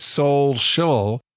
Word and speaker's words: solecial: And it's spelled S-N-E-A-K-E-R. And then the next part of it is S-O-L-E-C-I-A-L. So it's soul shul. And that makes sense because of the soul part.